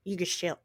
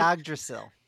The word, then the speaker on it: Yggdrasil: It's a mystical, magical rainbow road that connects the nine realms together through Yggdrasil. I'm not even pronouncing that correctly. I was pronouncing it correctly earlier today. – Yggdrasil. – Thank you.